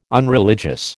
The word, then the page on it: unreligious: American English